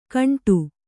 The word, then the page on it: ಘಂಟಾ: kannada